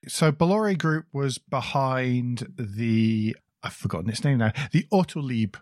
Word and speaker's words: Bolloré: and they have bought Blue Point London from Bolliere. Is that how you pronounce it? – Bolloré.